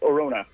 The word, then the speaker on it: Arona: How do you pronounce your last name? Or do you want to say your last name? You want to just say? – Yeah, no, David Arona. – David Arona. Okay, I thought that was Arona. I don't want to pronounce it wrong. I'm woke. I won't be disrespectful of your culture, sir. – I don't think Chris rolled that R enough, though, but we'll let it go.